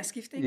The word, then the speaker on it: Skifting: In 2013, for instance, you did, I'm going to pronounce this incorrectly, Skifting? – Yeah, Skifting, yeah.